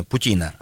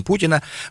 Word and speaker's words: путина: Рыбаков-браконьеров задержали в Ростовской области, об этом сообщает пресс-служба управления транспорта МВД России по Северокавказскому федеральному округу во время операции «Путина» 2019, нужно правильно ставить ударение, «Путина», а не Путина, вот, на берегу Дона в Ростове-Цивлянском водохранилище, да.